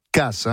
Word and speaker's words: Cas: On dit, alors, ça s'écrit C-A-S et ça se prononce Casse, hein, Gavitz.